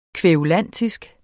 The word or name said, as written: kværulantisk